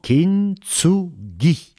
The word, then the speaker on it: kintsugi: Alors, on peut dire simplement « kintsugi ».